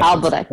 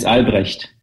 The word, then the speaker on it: Albrecht: Albrecht.